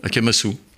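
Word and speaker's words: Akamasoa: J'ai vu que, alors je ne sais pas comment on prononce, Akamaso. – Akamasu. – Akamaso, ça s'écrit AK, je dis ça pour nos auditeurs, MA. SOA, je le dis parce qu'il n'est pas interdit d'aller sur Google et puis de donner un peu d'argent.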